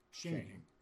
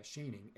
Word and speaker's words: Schoening: Schoening. – Mm-hmm. Yeah, Schoening. And that's spelled S-C-H-O-E-N-I-G. – N-I-N-I-N-G. – N-I-N-G.